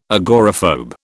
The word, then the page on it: agoraphobe: British English